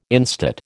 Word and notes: instate: American English

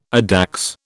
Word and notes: addax: British English

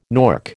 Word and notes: nork: American English

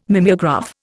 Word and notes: mimeograph: Indian

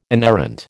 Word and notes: inerrant: American English

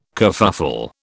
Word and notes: kerfuffle: British English

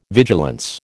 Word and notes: vigilance: American English

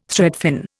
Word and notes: threadfin: Indian

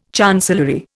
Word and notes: chancellery: Indian

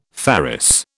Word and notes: ferrous: British English

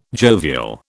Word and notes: jovial: British English

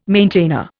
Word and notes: maintainer: Indian

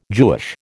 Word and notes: Jewish: American English

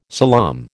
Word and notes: salaam: American English

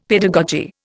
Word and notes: pedagogy: Indian

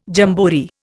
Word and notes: jamboree: Indian

